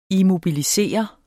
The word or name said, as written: immobilisere